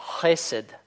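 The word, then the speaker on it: хесед (חסד): Да, вот скажем слово «милость», верная преданность, это начинается с буквы хет и произносится, соответственно, хесед, хесед, хесед, да. Вы можете сказать, в принципе, хесед, то есть просто как русское хэ, хесед. Это допустимо, но это не вполне точно.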